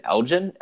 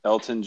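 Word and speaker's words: Elgton: Is it Elgin Elton Jenkins? Elton Jenkins. So silent G, silent G.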